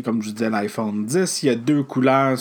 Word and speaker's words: iPhone X: Donc, euh, je vais passer rapidement sur l'iPhone X, mais qu'il faut prononcer iPhone 10.